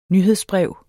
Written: nyhedsbrev

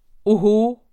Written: oho

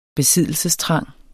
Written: besiddelsestrang

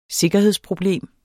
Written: sikkerhedsproblem

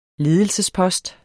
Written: ledelsesposter

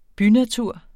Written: bynatur

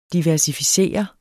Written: diversificere

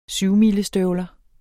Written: syvmilestøvler

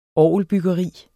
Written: orgelbyggeri

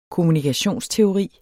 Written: kommunikationsteori

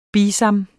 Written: BISAMPELS

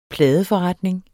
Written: pladeforretning

